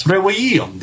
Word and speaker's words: trivium: there's no V sound. It's trivium. That's how that word is actually pronounced.